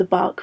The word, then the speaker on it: Bach: which is the bark flower remedies, which is spelled B-A-C-H, bark flower remedies.